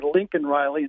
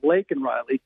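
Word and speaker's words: Laken Riley: But after people were blasting Biden for his mispronouncing the name of the lady murdered by an illegal immigrant by calling her Lincoln Riley instead of Lakin Riley at the State of the Union speech,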